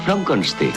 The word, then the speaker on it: Frankenstein: Frankenstein. Se pronuncia Frankenstein.